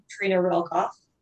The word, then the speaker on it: Treena Reilkoff: Um, on LinkedIn, I guess you would put my name in Treena Reilkoff.